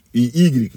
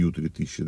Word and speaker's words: Y3000: Вот ют, и Y, надо правильно говорить, а не U3000, да.